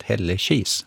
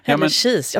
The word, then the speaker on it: Hällekis: Och slår man upp Hällekis då, så står uttalet Hällekis. – Ja, nej men jag ändrar mig alltså. Det är ja. Säkert så. Hällekis, jag ska bara säga det nu.